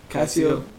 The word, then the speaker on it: Casio: Casio.